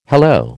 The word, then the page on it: hello: American English